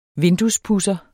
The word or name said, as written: vinduespudser